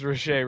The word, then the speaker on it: Reche: How the fuck do you pronounce that? Reche? – Reche. – Oh. – It's Reche.